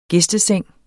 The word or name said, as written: gæsteseng